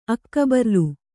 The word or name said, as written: ಅಕ್ಕಬರ್ಲು